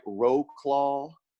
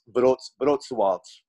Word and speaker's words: Wrocław: It looks like Roclaw. It's like Wroclaw, but it's pronounced Wrocław.